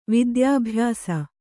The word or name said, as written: ವಿದ್ಯಾಭ್ಯಾಸ